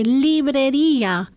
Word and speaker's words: librería: So what, I have trouble with the pronunciation. Libreria? – Libreria.